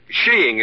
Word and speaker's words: skiing: Oh, there are other pronunciations, I suppose, but in England and Norway, sheeing is considered most acceptable.